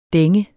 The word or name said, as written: dænge